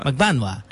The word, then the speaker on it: Magbanua: Is that how you pronounce it? Magbanua? – Magbanua. – Yeah. I like Magbanua.